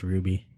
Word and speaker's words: RWBY: I think it's pronounced Ruby.